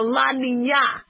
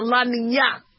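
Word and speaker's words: La Niña: There's also an El or a La Nina. Okay. And if you thought El Nino was bad, wait till you hear about La Nina.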